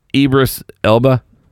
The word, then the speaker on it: Idris Elba: Idris Elba, is that how you say it? – Yes. – Idris.